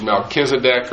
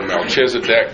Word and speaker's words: Melchizedek: One king was Melchizedek, or Melchizedek, depending on how you choose to pronounce it. Don't let anybody make fun of you if you pronounce it one way versus the other way.